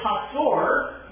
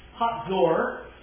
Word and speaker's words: Hazor: And the city of Hazor, that's how you pronounce that, their Z's sound as if they have a T in front of it. Hazor is today another of these amazingly wonderful archaeological sites.